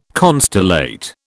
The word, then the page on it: constellate: British English